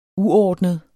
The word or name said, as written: uordnet